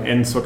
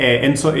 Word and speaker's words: Enzo: Um, on Twitter, Twitter, I'm Enzo Cavalie, just my, like my name. And sure, yeah, let's how connect. Spell, how do you spell it again? Uh, Enzo, it's uh, E N Z O C A V A L I E.